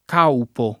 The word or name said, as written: Caupo